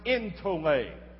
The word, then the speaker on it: entole: The word is pronounced entole, E-N-T-O-L-E. When you have an E sound written like that in transliteration, it's pronounced as an A because that last letter in the Greek looks like a little, looks like an N with a long line at the bottom of it. It goes like that, and it's an eta. And it's pronounced as an A. That's why we pronounce it entole.